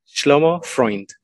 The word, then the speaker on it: Shlomo Freund: Shlomo Freund.